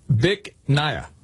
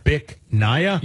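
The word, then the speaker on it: Bich Nga: which he was going to have a little fun with, because the name of the restaurant, you pronounce it Bic Nia. – Bic Nia? – Yes, but it's spelled B-I-C-H, one word. Yeah. Second word, N-G-A.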